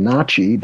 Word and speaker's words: Natchez: Just for our listeners, when I'm saying Na'Chi, that is the traditional pronunciation.